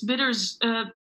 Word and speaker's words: Bitterzoet: Did I say that right? Bitterzoet? – Yeah. Almost. It's Bitterzoet.